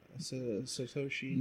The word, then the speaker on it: Satoshi: Satoshi Nakamoto.